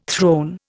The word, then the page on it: throne: Indian